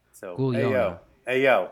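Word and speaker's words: guaglione: The Nablodons have a word pronounced Gugliona. and there's a yo in the middle of it. Yona.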